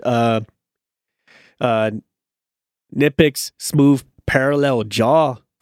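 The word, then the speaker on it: Knipex: So now now now you have uh a good you're probably gonna have a good swarm of people like, no, it's nitpicks. The K is silent.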